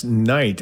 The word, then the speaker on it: Nite: That's Knight, and it's spelled N-I-T-E, by the way.